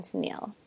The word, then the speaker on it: Tennile: Welcome, Tennile.